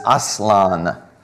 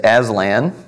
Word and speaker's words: Aslan: Interestingly, the Turkish word for lion is aslan. And C.S. Lewis, he wrote The Lion, the Witch, and the Wardrobe, in which he created a character, a lion, which we as Americans pronounce typically as Aslan.